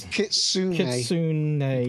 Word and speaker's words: Kitsune: It's Kitsune books. – You pronounce the E.